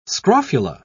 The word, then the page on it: scrofula: British English